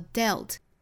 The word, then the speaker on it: dealt: 那注意发音哈，从 deal e 的元音变成了 dealt e 的元音了。